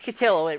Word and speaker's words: Cotillo: Oh, Chris Cotillo. It rhymes with pillow.